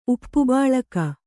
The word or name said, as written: ಉಪ್ಪುಬಾಳಕ